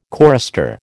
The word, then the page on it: chorister: American English